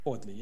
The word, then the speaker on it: Fordley: In that case, in Fordley, if I'm saying the name right. – Fordley, yes.